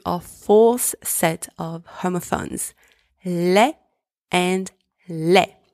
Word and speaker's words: lait: The first one, spelled L-A-I-D, means ugly. The second one, spelled L-A-I-T, means milk. In both of these words, the letter L is pronounced L. The letters A and I together are pronounced E, and the final consonants, D and T, are both silent.